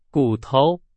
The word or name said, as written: 骨头